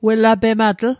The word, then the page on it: welapematl: Mi'gmaq